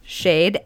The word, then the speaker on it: SHAED: Shade, S-H-A-E-D.